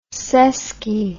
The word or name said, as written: sesky